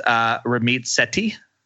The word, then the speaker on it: Ramit Sethi: Ramit Sethi.